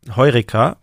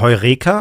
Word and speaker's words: Heureka: Also, und, und zwar bei eurem, bei dem letzten Album von Tomte, Heureka ja. oder Heureka, auf welcher Silbe betont man das eigentlich? Kann man machen, wie man will.